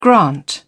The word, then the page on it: grant: British English